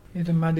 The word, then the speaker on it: irrumardi: Edamame is brim, the fish brim, and it's spelt IRRUMARDI. Can you say Edamame?